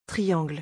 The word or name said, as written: triangle